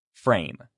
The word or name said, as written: frame